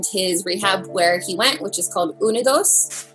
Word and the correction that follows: Unidos: I called it Unidos the whole time. Didn't know it was a Spanish word. Unidos is how you say it.